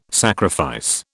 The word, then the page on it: sacrifice: British English